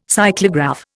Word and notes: cyclograph: Indian

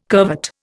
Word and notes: curvet: Indian

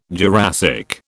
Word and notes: Jurassic: British English

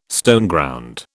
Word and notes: Stoneground: British English

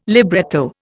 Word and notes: libretto: Indian

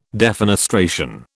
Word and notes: defenestration: British English

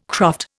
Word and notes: croft: Indian

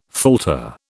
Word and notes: falter: British English

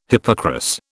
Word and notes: hippocras: British English

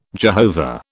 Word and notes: Jehovah: British English

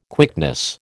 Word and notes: quickness: American English

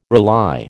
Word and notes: rely: American English

